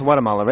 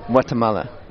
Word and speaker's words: Guatemala: And it's pronounced Guatemala, right? – Yeah, Guatemala. – Guatemala. – Yeah, exactly, Guatemala.